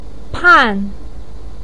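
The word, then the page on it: pan4: tone 4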